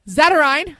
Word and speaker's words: Zatarain: Zatarain, I did it. Yay. – There you go. – There I go.